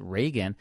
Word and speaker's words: Reagan: They're all saying Regan, and they would have had rehearsals prior to this, and he would have said, well, I'm pronounce it Reagan. Maybe he just went by Regan back then, and then as he got into government work, maybe he said, okay, I want to go by Reagan.